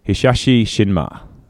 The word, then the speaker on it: Hisashi Shinma: Now, the politics of New Japan Pro Wrestling, their main promoter was a guy called, I haven't pronounced this properly, Hisashi Shinma. Am I correct there? – Yeah, close enough.